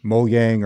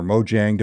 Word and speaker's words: Mojang: And when they acquired Mojang or Mojang, depending on who you're talking to, Phil Spencer, actually, the head of Xbox, has, I think, switched the pronunciation of that.